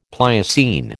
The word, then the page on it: Pliocene: American English